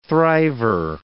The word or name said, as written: thriver